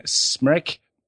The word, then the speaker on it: Smrek: Stan Albeck wanted to keep Dave Corzine in his regular role off the bench, leaving the Bulls' 12th man, Smrek, pronounced wreck as in nervous wreck, wrote the sack.